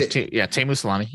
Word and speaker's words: Teemu Selänne: This is, and forgive me if I say this wrong, Teemu Selänne. – Yep. Okay. Close enough. Teemu Selänne. You're good.